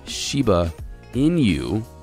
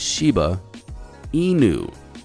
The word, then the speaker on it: Shiba Inu: Because this listener sent an email and said, bro, you've been pronouncing Shiba Inu wrong this whole time. It's not, I've been saying Shiba Inu, but it's Shiba Inu.